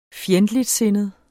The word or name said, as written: FJENDTLIGTSINDET